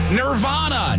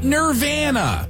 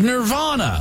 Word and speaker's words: Nirvana: Nirvana, not Nirvana. It is Nirvana. I have to correct my 20-something-year-old nephew who annoys me constantly by trying to say it wrong, to aggravate me.